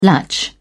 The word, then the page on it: latch: British English